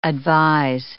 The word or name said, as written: advise